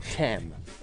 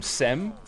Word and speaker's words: Cem: It's spelt C E M, but it's pronounced G E M. Oh. Like Kem. And so I've been calling him Sem mm.